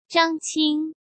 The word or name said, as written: Zhang Qing